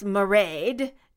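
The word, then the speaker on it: Mairead: Anyway, the name Married is pronounced Maraid, just a heads up."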